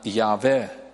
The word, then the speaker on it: Yahweh: De heerlijkste naam van God is echter de naam Yahweh of Jehovah. Het is een naam die in het Hebreeuws uit 4 letters bestaat. J-H-W-H. En uitgesproken wordt als Yahweh.